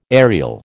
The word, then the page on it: aerial: American English